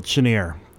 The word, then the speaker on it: Chenier: Chenier, maybe you think you should pronounce it, but in the United States, they called it Chenier. Clifton Chenier. That's how he was introduced always.